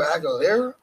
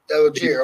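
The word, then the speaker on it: Allgeier: The other one you talked about, Atlanta, and I'm going to butcher his name. You can help me on that one. But Tyler Aguilar? – Algier.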